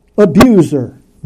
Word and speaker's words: abuser: Abuser, noun. The S is pronounced as a Z.